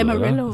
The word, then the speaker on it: Amarillo: Amarillo oder so. Amarillo.